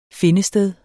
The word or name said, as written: findested